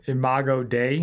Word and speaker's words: Imago Dei: Imago Dei. – Imago Dei and equal protection.